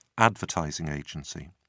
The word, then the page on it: advertising agency: British English